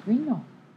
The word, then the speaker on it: Grignon: Well, what it is Green-O. If you think about G-R-E-N-O, and then you think of Grignion, the way it's pronounced, versus what the way it's spelled, G-R-I-G-N-O-N, it's the same name.